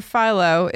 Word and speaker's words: Philo: The TV provider, Philo, is pronounced like the inventor of TV's name, Philo T. Farnsworth.